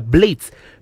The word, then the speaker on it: Blades: por lo tanto la pronunciación correcta sigue la fonética inglesa, sonando como blades, parecido a la palabra blades, que significa cuchillas, navajas, hojas en inglés.